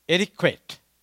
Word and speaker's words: etiquette: You might have heard the word etiquette. Am I pronouncing that correct? It's a French word.